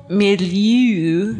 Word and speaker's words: milieu: combing faiths in a sort of cultural milieu.